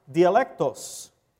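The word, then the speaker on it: dialectos (διάλεκτος): In Greek, it's pronounced dialectos. Sounds like dialects.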